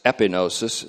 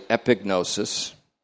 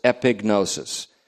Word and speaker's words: epignosis: Epi, E-P-I, then G-N-omega-O-S-I-S. Epinosis. And we used to pronounce it that way, but the G is actually pronounced in this, so it's epignosis.